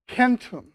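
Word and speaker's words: centum: Kentum. Okay, unfortunately, we pronounce it, we change the pronunciation. So, centennial, cent, and so on. That is that's our pronunciation of Latin, Kent.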